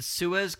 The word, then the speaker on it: Suez: The Suez Canal. – Suez Canal. – You wrote Seuss, like Dr. Seuss.